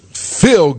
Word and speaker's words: Phil: Phil, like I feel good. – Phil Good. Phil, what's your last name? – Trull rhymes with dull. – T-R-U-L-L? – That's correct.